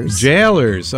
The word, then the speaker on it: Gaolers: How do you say that? – Jailers. – Okay. – Yeah, it's the old British way to say jailer, like the prison guard.